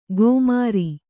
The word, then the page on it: ಗೋಮಾರಿ: kannada